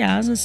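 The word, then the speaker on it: Asaas: Lembrando que Asas se escreve A-S-A-A-S e conheça a conta conta digital completa para empresas.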